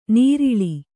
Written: ನರಳು

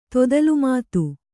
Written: ತೊದಲುಮಾತು

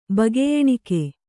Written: ಬಗೆಯೆಣಿಕೆ

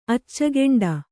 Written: ಅಚ್ಚಗೊಂಡ

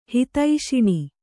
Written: ಹಿತೈಷಿಣಿ

